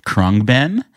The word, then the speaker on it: Khruangbin: They're called Krungban. It's spelled KHRUANGBIN.